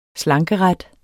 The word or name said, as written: slankeret